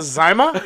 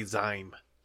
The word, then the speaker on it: Zima: It's spelled like lima bean. Lima. It's Zima.